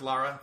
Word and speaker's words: Lara: she's dressed as Laura Croft. Hey, Laura. You know. – It's pronounced Lara.